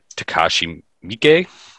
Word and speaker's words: Takashi Miike: The director is Takashi Miike.